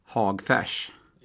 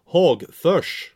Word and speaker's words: Hagfors: Ja, hur uttalar man det eh, när man är därifrån? – Hagfärs. Ja, Hagförs.